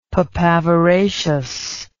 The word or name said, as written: papaveraceous